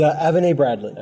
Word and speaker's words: Avenet-Bradley: How do you say the name now? Avenet? – It's Avenet-Bradley.